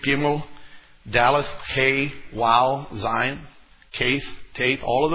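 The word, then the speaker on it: Waw: So what you're looking at here is called a WOW. It is the equivalent in English of our letter W phonetically.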